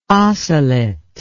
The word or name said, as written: osselet